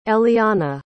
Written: elliana

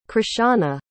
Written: chrishauna

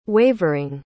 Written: wavering

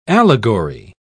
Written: allegory

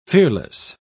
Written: fearless

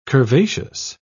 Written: curvaceous

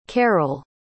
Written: karyl

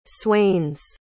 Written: swains

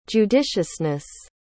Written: judiciousness

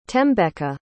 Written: thembeka

